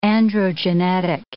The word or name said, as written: androgenetic